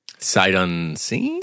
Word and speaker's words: sight unseen: it can't just go away unsight, unsound. Am I saying that right? – Sight unseen? – That's what I'm trying to say. It can't just go away sight unseen.